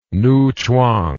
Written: newchwang

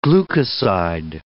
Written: glucoside

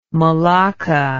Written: melaka

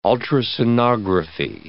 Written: ultrasonography

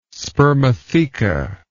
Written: spermatheca

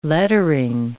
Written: lettering

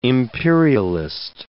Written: imperialist

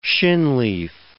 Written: shinleaf

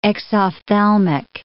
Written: exophthalmic

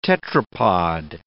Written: tetrapod

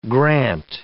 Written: grant

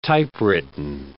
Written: typewritten

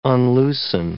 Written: unloosen

